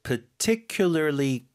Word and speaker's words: particularly: I was particularly good at anything. 뭐 딱히 잘하는 게 없다는 느낌을 받았다. 이 얘기잖아요. Particularly good. 자, 그 발음 좀 도와드릴게요. – Particularly. – 첫 번째 발음 파가 아니라 그냥 particularly.